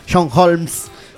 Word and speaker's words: Holmes: Tenemos al actor porno muy conocido de los años 80, 70, 80, John Holmes. Se escribe con una E al final entre la S y la M. Y el artista sueco se llama John Holm, sin la E, pero fonéticamente suena igual.